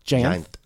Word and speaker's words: Jayanth: Jayanth, two syllables. – Like giant with a lisp. – Jayanth.